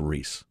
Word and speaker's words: Rhys: Well, I'm guessing it's Reese just because Matthew Reese, the star of The Americans, and currently on the home box office in The Perry Mason Show, pronounces R-H-Y-S as Reese. But this I believe it is Welsh.